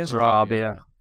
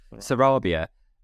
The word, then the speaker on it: Sarabia: Sarabia. – Sarabia.